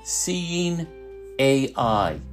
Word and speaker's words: Seeing AI: Uh, and finally, I'd like to mention uh, the Microsoft program called Seeing AI. Uh, if you pronounced it, it'd be Seeing Eye, which is the oldest guide dog service in the country.